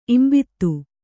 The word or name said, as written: ಇಂಬಿತ್ತು